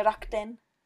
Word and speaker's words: Rakuten: Also Rakuten, Rakuten. – Achso, ja, ja, ja, ja, ja, klar. – Also mhm. auf Japanisch sagt man ja Rakden. Rakten. Das U wird dann nicht auf, ausgesprochen mhm.